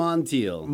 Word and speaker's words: Montiel: Montiel.